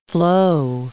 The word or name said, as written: flow